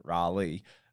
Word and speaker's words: Raleigh: Raleigh.